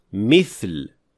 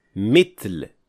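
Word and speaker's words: mithl (مثل): And others make the mistake of pronouncing tha as ta. So instead of saying mithl, they would say mitl.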